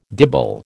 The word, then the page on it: dibble: American English